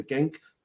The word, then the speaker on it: Genk: I think it's called pronounced Genk, G-E-N-K.